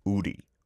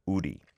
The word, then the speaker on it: 우리: But when you see it romanized, like there's a bank that's called that, it's W-O-O-R-I, so it looks like woori as well. But the correct pronunciation in Korean, uri, uri.